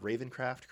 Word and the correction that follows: Ravencroft: crazy, Matt? – Ravencroft crazy. Yeah, it doesn't have the same ring to it. – Yeah, I appreciate you pronouncing it correctly.